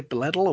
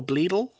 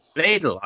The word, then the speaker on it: Bledel: Alexis Blaisdell, how do you say her name? – I'd say Bledel or Bleedle. – Bledel. I was putting an extra S in there.